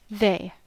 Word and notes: they: American English

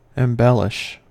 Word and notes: embellish: American English

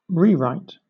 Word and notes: rewrite: British English; noun

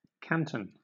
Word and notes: canton: British English

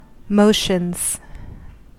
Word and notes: motions: American English

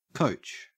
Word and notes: coach: Australian English

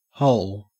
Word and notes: hole: Australian English

